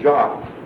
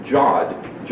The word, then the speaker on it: yod: And you can see an example of the jot in Psalm 119, verse 73, called Jod, J-O-D, but also could be pronounced Jot, J-O-T, or Yod, Y-O-D, and so forth.